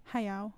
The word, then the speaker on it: Hayao Miyazaki: Hayao Miyazaki? – Yeah. Hayao.